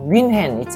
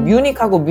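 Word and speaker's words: München: 근데 그런 느낌도 있는데, 우리말로 외국어를 할 때, 예를 들어서, 윈헨 있잖아요. 이 독일의 도시. 이거 원어 발음에 더 가까워요. 윈헨인데, 우리는 윈헨이라고 하고, 영어로는 뮤닉이거든요. 뮤닉하고 윈헨은 완전 다르잖아요.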